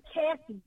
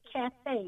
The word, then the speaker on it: Cathay: The name, and I want to say Cathy, but I'm going to say Cathay, because that's how it's spelled. C-A-T-H-A-Y. Repeat, C-A-T-H-A-Y. Cathay Williams.